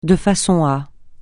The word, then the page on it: de façon à: francais